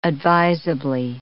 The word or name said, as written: advisably